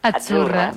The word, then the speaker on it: Azzurra: Azzurra. Mira, ves, es que azzurra. Sí, porque pizza, la Z no es la Z como decimos en catalán tan sonora, no. Es azzurra.